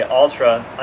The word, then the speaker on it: Altra: And by the way, Altra, I'm saying this for people like me. It's spelled A-L-T-R-A because for a long time I was like, what are you saying now? – Yeah.